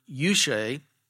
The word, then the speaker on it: euche: They use the Greek word euche, and it's spelled E-U-C-H-E,